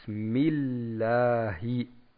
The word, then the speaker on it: Bismillahi: sagt man bis Bismillahi. Das H muss man aussprechen, nicht vergessen.